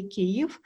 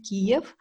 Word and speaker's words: Kyiv: Uh, Ukraine is a country which is situated in Europe uh, with the city of Kyiv as its capital. Uh, important note uh, to um, every foreigner. It is actually correct to say Kyiv, not Kiev.